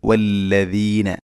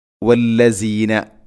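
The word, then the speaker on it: وَالَّذِينَ: അപ്പോഴാണ് വല്ലദീന ഇങ്ങനെ ശബ്ദം വരികയുള്ളൂ. പലപ്പോഴും പല ആളുകളും ഓതുന്നത് വല്ലസീന എന്ന രൂപത്തിലാണ്, വല്ലസീന അല്ല. വല്ലദീന യുമിനൂന, യുമിനൂന.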